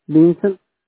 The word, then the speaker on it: Vicente: Vincent, V-I-C-E-N-T-E. – V-I-C-E-N-T-E? Is that right? – Yes. – V-I-C-E-N-T-E.